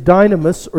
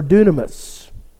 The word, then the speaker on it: dynamis: One of them means it's exousia, and the other is dynamis or dunamis. Either way, it can be pronounced either way.